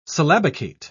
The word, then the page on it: syllabicate: British English